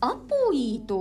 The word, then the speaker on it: APOE: ちなみにこれ APOE でアポイと読むそうです。